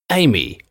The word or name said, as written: Amy